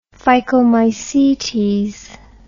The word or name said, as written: phycomycetes